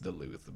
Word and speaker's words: Duluth: I mean, it's pronounced Duluth, but I like to call it Duluth.